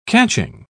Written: catching

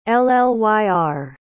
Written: llyr

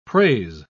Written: praise

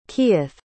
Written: kieth

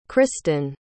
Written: Krystin

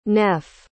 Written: neff

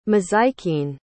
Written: mazikeen

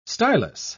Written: stylus

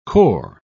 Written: cor

